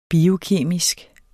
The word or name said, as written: biokemisk